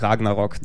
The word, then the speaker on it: Ragnarök: Ja, aber ich glaube, das spricht man nicht aus, das Ö. Irgendwie, man schreibt es nur hin. – Ist wahrscheinlich so ein Heavy-Metal-Umlaut. Sag einfach Ragnarok, dann passt es schon.